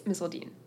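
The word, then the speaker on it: Misseldine: A lot of people think Misseldean. – Yeah. – Because it's spelled D-I-N-E. – Yeah. – But my whole life it's been Misseldean.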